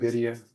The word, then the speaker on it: birria: We got that birria.